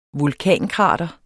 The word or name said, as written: vulkankrater